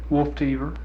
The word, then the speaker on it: Wolftever: Wolf Tever.